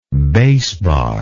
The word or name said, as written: bass bar